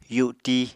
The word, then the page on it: yuti: apatani